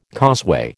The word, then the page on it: causeway: American English